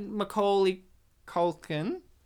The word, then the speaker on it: Macaulay Culkin: You had Macaulay Culkin.